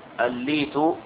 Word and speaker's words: الليت: هذا تمت الإجابة عنه، ولكن هناك كلمة نطقتها وهي كلمة لي، قلت ليت، والصواب بكسر اللام، أصغى ليتا ورفع ليتا، طبقها بكسر اللام، الليت بكسر اللام وليس بفتحها.